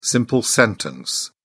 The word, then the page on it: simple sentence: British English